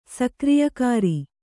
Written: ಸಕ್ರಿಯಕಾರಿ

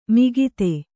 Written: ಮಾಗತೆ

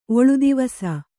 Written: ಒಳುದಿವಸ

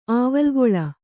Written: ಆವಲ್ಗೊಳ

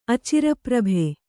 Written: ಅಚಿರಪ್ರಭೆ

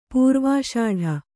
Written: ಪ್ರವೇಷ್ಟ

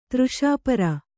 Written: ತೃಷಾಪರ

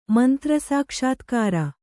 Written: ಮಂತ್ರಸಾಕ್ಷಾತ್ಕಾರ

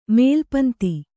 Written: ಮೇಲ್ಪಂತಿ

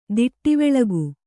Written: ದಿಟ್ಟಿವೆಳಗು

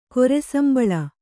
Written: ಕೊರೆಸಂಬಳ

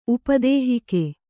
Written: ಉಪದೇಹಿಕೆ

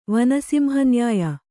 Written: ವನಸಿಂಹನ್ಯಾಯ

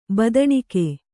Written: ಬದನಿಕೆ

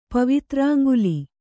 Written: ಪವಿತ್ರಾಂಗುಲಿ

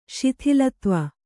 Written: ಸ್ಥೂಲತ್ವ

